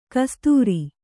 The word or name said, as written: ಕಸದಾರ